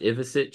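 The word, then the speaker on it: Ivišić: I think Zvonimir's last name is pronounced Ivicic.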